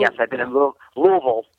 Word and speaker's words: Louisville: Well, it's Louisville, because anywhere that's got a vol, which is a lot of places, especially in Tennessee, we've got like a Nashville, Knoxville, Shebville.